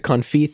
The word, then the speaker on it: confit: And it's C-O-N-F-I-T. – Okay. I pronounce it confit, which is how I heard it way back when. And I don't really know that there's another way to pronounce it. It's definitely not confit. Like, if you said confit, I would have no idea what you're talking about.